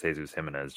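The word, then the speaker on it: Jesús Jiménez: Well, I'd imagine it's Spanish. So it's like he is from Spain. So it's Jesus Jimenez, just based okay. on, you know, pronunciation.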